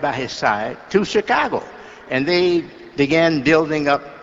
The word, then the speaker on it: Chicago: Once I was listening to him on television talking about the great city, our great city of Chicago, and he said, you don't pronounce it she. Chicago. It's chi, like chick, you know, or like chicken.